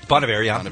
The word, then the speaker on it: Bon Iver: Bon Iver.